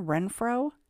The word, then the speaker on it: Renfro: Coincidentally, or perhaps not, the pawn ticket was signed by a Deanna Renfro, R-E-N-F-R-O. The occupants of the brown van mentioned above were the Renfro family, R-E-N-F-R-O-W. Both names are pronounced the same, but one has a W added in the end.